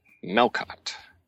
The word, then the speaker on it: Melkot: Melkot. – What? Say that again. Melkot? – Melkot. – Melkot. Can you spell it? – M-E-L-K-O-T.